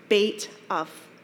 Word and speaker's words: Beit Av: I asked my friend Jeremy from Fairhaven, and he told me it's pronounced Beit Av.